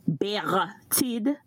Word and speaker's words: Bertide: This is Bertide, and I'm going to pronounce my name like we do from the island. Bertide is the name, so that's the pronunciation.